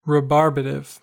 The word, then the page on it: rebarbative: American English